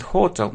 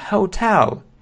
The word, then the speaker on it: hotel: Многие произносят hotel, но это неверно. O, звук O и tell. Hotel.